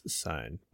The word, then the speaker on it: Sigyn: I don't know if it's Sign or like Sigyn. – I think it's Sign. But... – okay, that's what I thought.